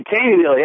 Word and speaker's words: Taneyville: And watching Free Speech TV on the Dish Network in Taneyville, Missouri. Am I saying that right? – Yeah, Taneyville, yeah, you got it. That's pretty darn close there, okay.